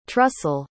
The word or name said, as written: trussell